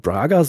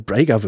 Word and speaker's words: Braga: Viertens, der Typ heißt nicht Brandon Braga, sondern Brandon Brager wird er ausgesprochen.